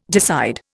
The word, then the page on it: decide: Indian